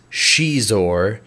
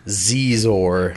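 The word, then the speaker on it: Xizor: The correct pronunciation is Shizor, but if you're not a fucking idiot, you're going to pronounce it Zizor. – Because it sounds way better than Shizor.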